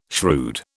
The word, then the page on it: shrewd: British English